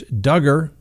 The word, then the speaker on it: Duggar: And then Josh Duggar, rhymes with hugger.